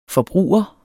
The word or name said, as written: forbruger